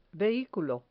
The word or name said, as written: vehiculo